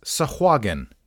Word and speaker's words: Sahuagin: S-A-H-U-A-G-I-N. According to Frank Mentzer's Dragon article, it is Sahuagin. Sahuagin. I probably have pronounced it Sahuagin, but it's just Sahuagin.